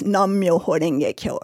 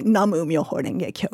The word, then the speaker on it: Namu Myoho Renge Kyo: in the phrase Nam Myoho Renge Kyo, or some people say Namu Myoho Renge Kyo. You hear both pronunciations.